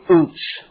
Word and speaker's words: Utz: Job lives in the land of Hebrew Utz. Like the potato chip company. U-T-Z. Utz.